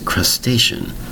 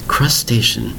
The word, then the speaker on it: crustacean: Today's word is crustacean. Crustacean. Three distinct syllables spelled C-R-U-S-T-A-C-E-A-N. Notice the spelling. Crustacean. C-R-U-S-T-A-C-E-A-N. You'll notice the last part of the word spelling is C-E-A-N. As a shun pronunciation.